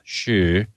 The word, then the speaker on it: Hsu: It's actually pronounced some different way in Chinese. Shu, I think, or so.